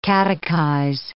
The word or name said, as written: catechize